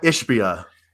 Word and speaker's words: Ishbia: Matt Ibiza. I think it's Ishba. – It's Ibiza. He goes Ibiza. No, it's Matt Ishbia. Yeah.